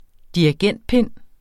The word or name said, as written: dirigentpind